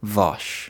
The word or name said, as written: Vaush